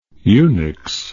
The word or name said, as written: unix